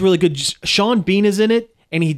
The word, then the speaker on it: Sean Bean: I believe it's pronounced Sean Bean. – Sean Bean, yeah. My mistake.